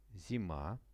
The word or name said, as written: zima